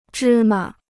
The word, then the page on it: 芝麻: zhī ma